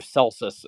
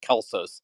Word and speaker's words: Celsus: it's usually described as the library of Celsus. It's really probably best pronounced Kelsos.